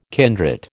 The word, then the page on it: kindred: American English